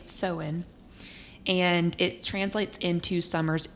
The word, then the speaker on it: Samhain: I've always pronounced it Samhain, but it's Samhain. – It's spelled Samhain, so I love Samhain.